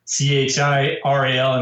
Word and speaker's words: Chiral: Chiral, and I know like no fan is going to pronounce it that way,